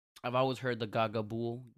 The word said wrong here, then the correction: gabagool: meme. I'm not sure if I'm pronouncing it right. – Gabagool. – Gagaboo. What is Gagaboo? Gagaboo. – Like, Gabagool. – What? – Gabagool. – Oh, Gabagool.